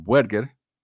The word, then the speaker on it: Buerger: El autor, David ah, John Berger, Buerger, creo que en inglés se pronuncia Berger, pero Berger, el libro del autor Los Misterios de la Divinidad, una historia de la adoración del Templo Mormón.